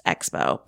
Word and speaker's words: XPO: It's spelled that way, yes. – There's no E. – It's pronounced Expo, but right, there's it's just they XPO. Skip the E.